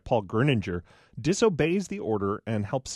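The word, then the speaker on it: Grüninger: You did pronounce his name rightly, Gruninger, and he's really a remarkable character.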